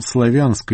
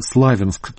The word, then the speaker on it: Славянск: Алекс Нойт упомянул Славянск или Славянск, так обычно ставят ударение местные жители.